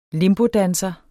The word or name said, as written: limbodanser